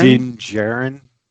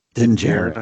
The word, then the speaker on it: Din Djarin: Din Djarin. – Din Djarin, I think.